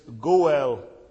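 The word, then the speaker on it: Goel (גואל): It is Goel. You would spell it in English G-O-E-L.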